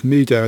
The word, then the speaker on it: Mehdoh: But a new one that caught my eye, or rather it's updated, which is why it caught my eye, is Mehdoh, and that's spelled M-E-H-D-O-H.